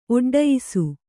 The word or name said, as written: ಒಡ್ಡಯಿಸು